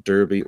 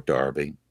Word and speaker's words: Derby: Well, they stormed into Derby or Darby. We pronounce it the American way. We don't say Milano or Paris or Cologne. We just pronounce it the good old American way. Mm-hmm. But to make our English viewers happy, I'll say Derby.